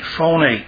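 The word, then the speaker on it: phone (φωνή): G-L-O-S-S-A. But the word languages in this verse is translated from the Greek word phone, like it's like the word phone with an accent on the E, phone,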